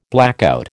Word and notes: blackout: American English

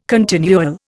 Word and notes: continual: Indian

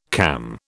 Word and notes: cam: British English

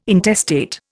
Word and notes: intestate: Indian

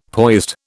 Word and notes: poised: British English

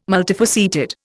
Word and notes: multifaceted: Indian

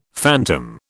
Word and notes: phantom: British English

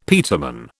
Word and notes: peterman: British English

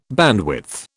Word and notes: bandwidth: British English

